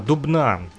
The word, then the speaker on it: Дубна: Вот, любит граффити, да, я сказал Дубна, Дубна называется этот город на самом деле, чтобы вы вот правильно слышали и называли ударение, а то я вот с ошибками иногда говорю.